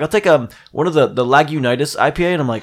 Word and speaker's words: Lagunitas: Yeah, Lagunitas.